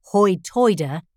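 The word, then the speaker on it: hoi toider: The origin of the dialect's name, hoi-toider, is a question of pronunciation. So, high is pronounced hoi and tide is pronounced hoid. Thus, the speakers are hoi-toiders.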